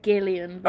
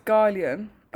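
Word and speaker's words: Guylian: I say Gillian. – Oh, I think it's Gillian.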